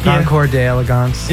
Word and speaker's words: Concours d'Elegance: And not this weekend, but next weekend, the Concours d'Elegance. – I know, right? – Is that how you pronounce it? – Yeah. Concours d'Elegance.